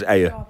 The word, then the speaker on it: Ayr: Is that how you pronounce Ayr? – A-Y-R. Ayr. – Is that how they pronounce it? In Ayr. – Is it? – Ayr. – Yes. – Ayr. – Pronounced it right.